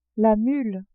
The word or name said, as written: mule